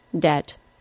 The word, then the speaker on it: debt: Your first lesson of this podcast, then, is that you can be confident in dropping the B sound from the words subtle, debt, and doubt. In fact, pronouncing the B sound is wholly incorrect.